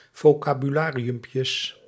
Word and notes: vocabulariumpjes: Dutch

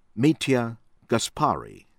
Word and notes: Mitja Gaspari: from Slovenia

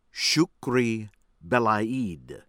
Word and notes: Chokri Belaid: American English

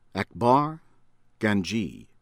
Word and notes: Akbar Ganji: American English; from Iran

